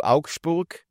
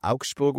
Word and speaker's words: Augsburg: Geht zurück in die Bundesliga zu Augsburg. Ja, ich habe bewusst Augsburg ausgesprochen, wenn man sagt es eigentlich so. – Gewusst. – Das ist halt Augsburg. So dort. Dort sagt man das so.